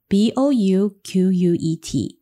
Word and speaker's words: bouquet: 第十三个是 bouquet，bouquet，它这个字呢，跟刚刚我们上面有提到的 bidet 有点类似，就它的 t 是不发音的，b o u q u e t。 b o u q u e t，但是记得哦，它的 t 是不发的。